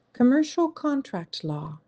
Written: Commercial contract law

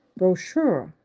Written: brochure

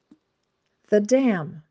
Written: the dam

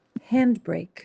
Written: handbrake

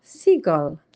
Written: seagull

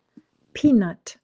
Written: peanut

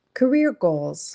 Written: career goal

